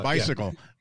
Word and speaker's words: Besiegel: Close enough, but bicycle. – Yeah. – Besiegel. Okay. – Bicycle. – Like you're saying bye to us.